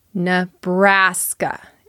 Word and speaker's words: Nebraska: That N-E at the beginning sounds like nuh, not ne. Nebraska. Stress on that second syllable.